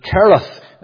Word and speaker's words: Cherith: "Hide thyself by the brook Cherith," that is before Jordan. And the Jewish way would be to pronounce it Kereth, the CH being the hard pronunciation.